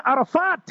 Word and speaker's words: Arafat: My name is Arafat bin Ibrahim Hatia. Just to the listeners, by the way, my name is spelled A-R-A-F-A-T, not F-double-A-T. And I see many, many listeners, they spell my name as Arafat. It's A-R-A-F-A-T.